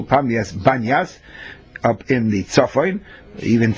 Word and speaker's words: Banyas: The reason why it's called Banyas, because that's the Arabic pronunciation for Pamias, but that's one and the same.